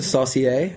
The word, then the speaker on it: saucier: One is a saucier. Is that how you say it? Okay. – Yeah.